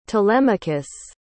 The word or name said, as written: telemachus